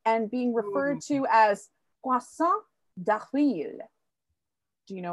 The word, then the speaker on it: poisson d'avril: No, I'm probably not saying it correctly. It's April fish. – Oh, poisson. Oh, sorry, I think it's poisson. Oh, okay. – Poisson d'avril. What did you think I said? – I, for some reason, I thought you said garçon. – Oh, no. Well, it's similar. – But you did, you pronounced it correctly.